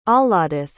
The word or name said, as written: ahladith